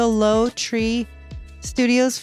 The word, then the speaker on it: Lowtree Studios: It's already up on the Jason and Mindy Facebook page, the Lowtree Studios Facebook page. Did I say that right? Yes.